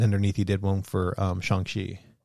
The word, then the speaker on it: Shang-Chi: So you said Shang-Chi, right? – Yeah. – Which is normal because it's S-H-A-N-G and then Chi, C-H-I. That's actually not how you pronounce that. – How do you pronounce it? – You pronounce it Shang. – Shang-Chi? – Shang-Chi. Yeah, S-H-O-N-G-C-H-E-E. Simu Liu, which is the main character, the lead actor playing Shang-Chi, he tweeted, I think, because this month is Asian Heritage Month.